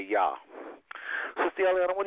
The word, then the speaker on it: Jah: J-A-H, and rejoice before him. So this is where we get the word hallelujah. We spell it J-A-H, hallelujah. But nobody say Jah. They say Yah because there was no J in the Hebrew.